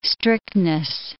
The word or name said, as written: strictness